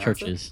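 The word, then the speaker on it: Church's: They actually look like they're from Churchy's. – Churchy's, you say? – Yes. Is that how you pronounce Churches.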